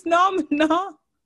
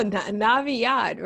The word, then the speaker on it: Naviyad: Did you say his name's Namna? – No, Naviad, right? N-A-V-I-Y-A-D.